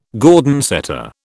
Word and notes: Gordon setter: British English

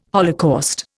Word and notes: holocaust: Indian

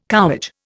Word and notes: cowage: Indian

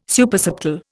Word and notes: Supersubtle: Indian

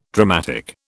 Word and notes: dramatic: British English

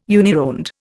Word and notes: unironed: Indian

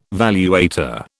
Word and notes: valuator: British English

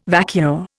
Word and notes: vacua: Indian

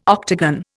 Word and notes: octagon: Indian

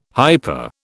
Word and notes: hyper: British English

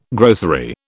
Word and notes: grocery: British English

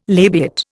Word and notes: labiate: Indian

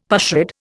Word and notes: pushrod: Indian

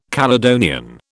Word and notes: Caledonian: British English

